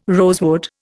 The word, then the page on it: rosewood: Indian